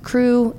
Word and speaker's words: Croux: C-R-O-U-X. Correct. Crew, it's spelled like roux, like the base of a dish, but it sounds like crew, join the crew.